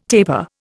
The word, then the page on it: tapir: Indian